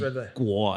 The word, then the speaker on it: Guo: All right, so Tai Chi Kyu. – Quo. – Quo. Oh, yeah, sorry. – Yeah, it's actually Guo, I think. Usually Guo. It's pronounced with a G, but if it's Taiwanese, I'll spell it with a K, but it's pronounced with a G.